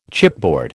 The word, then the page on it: chipboard: American English